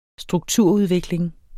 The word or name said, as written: strukturudvikling